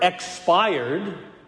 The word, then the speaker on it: expired: I think it can help you if you put the emphasis on the first syllable. Expired, exhaled.